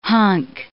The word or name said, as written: hunk